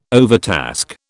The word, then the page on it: overtask: British English